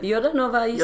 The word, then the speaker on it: Jordanova: Jordanova, said it correct.